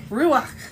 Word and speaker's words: ruach: It's Hebrew, so actually the way you would say it is ruach. – Ruach. – Gotta get that phlegm going there. – Okay. – So ruach.